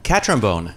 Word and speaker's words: Catrambone: George Cantrombone. COO, that did pretty well on that. – Catrombone. – Catrombone. – You just say it like it's spelled. – Say it like it's spelled. – Catrombone.